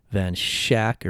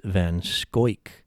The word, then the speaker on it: Van Schaick: His name is spelled V-A-N, capital S-C-H-A-I-C-K. Looks like it's pronounced Van Shack or Van Shake. I have been told by the Jackson County Historical Society, as well as the Wisconsin Historical Society, that is pronounced Van Schoik.